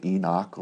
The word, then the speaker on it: Enoch: I thought it was pronounced Enoch. Why is it Enoch? Like there's an I in it. You can pronounce it Enoch or Enoch, either way.